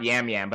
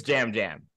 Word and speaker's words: Yam Yam: Abe was already confused why it's not Yam Yam, but I promise you it is Jam Jam. – Jam. – It is pronounced Jam. It is pronounced Jam Jam.